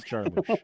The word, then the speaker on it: Jarmusch: Because we are looking at Only Lovers Left Alive from 2013, directed by Jim Jarmusch. Jarmusch, can you do the fandango? Sorry, Jarmusch. – Jarmusch. It's Jarmusch.